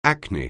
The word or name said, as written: acne